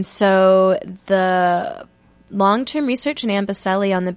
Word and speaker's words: Amboseli: Well, your work with the Amboseli, am mm-hmm.